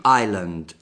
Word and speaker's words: island: È chiamato roundabout e anche island. Island, che è con l'S, però non si pronuncia l'S, che è la, che vuol dire anche isola.